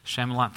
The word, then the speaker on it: Shyamalan: And with that, the, I don't even know how to pronounce his name, the M. Night Shyamalan, Shyamalan, thank you.